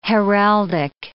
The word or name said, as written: heraldic